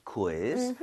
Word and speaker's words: quiz: 아, 그나저나 읽을 때 이거 퀴즈라고 하지 마시고요. 우이를 따로 다 발음해야 돼요. – Quiz. – 퀴즈. 그 대신에 아. – 빠르게 하는 것뿐이에요. So, quiz 아니라 quiz, quiz. 좀 이런 식으로 퀴 말고요.